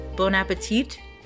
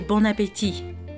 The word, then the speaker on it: bon appétit: And I remember one time I told them how like in America, for some reason, we'll say like bon appetit, like with a T sound at the end, but it's actually bon appétit, like the T at the end is silent.